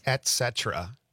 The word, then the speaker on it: et cetera: I don't like it when people say etc. – Yes. – Or espresso for coffee. – Espresso, right. – It's et cetera.